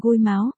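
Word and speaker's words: gôi máo (龜毛): Bản ro là gôi mao là một từ của giới trẻ Đài Loan, phát âm là gôi máo, nguyên nghĩa là tóc mọc trên con rùa.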